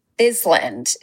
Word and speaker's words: Bisland: Bisland is how I've been pronouncing it for the past 2 years.